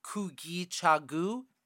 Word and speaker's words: Kujichagulia: It is, in Swahili, is pronounced Kujichagulia.